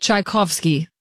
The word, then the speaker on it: Tchaikovsky: The biggest thing is that we just actually now pronounce the J in his name, because over there, the J is a CH. – No, it's a Y sound. That's why it's Chai. CZ is Ch. – Got it. – Chai Kov, because there used to be a W there, which is a V sound. – Got it. – Tchaikovsky, which actually is...